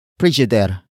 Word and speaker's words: pridyider: Ref is spelled as R-E-F. It is short for refrigerator. Another Tagalog word for refrigerator is frigider. Frigider. Frigider. Which is spelled as P-R-I-D-Y-I-D-E-R. This variant is borrowed from Frigidaire, a brand name of appliances.